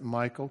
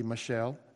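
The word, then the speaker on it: Michal: And as he comes into Jerusalem, his wife, M-I-C-H-A-L, I always pronounce it Michael, could be Michelle, but Michael.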